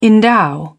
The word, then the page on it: endow: British English